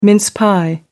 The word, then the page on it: mince pie: American English